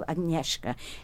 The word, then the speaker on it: Agnieszka: So you do say sh. See, yeah. – I said that for years and I thought I was corrected. I'm going back to Agnieszka. It's spelled A-G-N-I-E-S-Z-K-A.